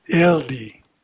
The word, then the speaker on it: Hildi: Soliani is pronounced as you would expect, and Hildi is Ildi, with the tongue doing something untranslatably sensual to the L sound.